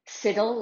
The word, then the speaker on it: Siddle: I sent Matt Lieta a little note with a kind of aid memoir that it's palant like talent, siddle like middle, and assel like castle.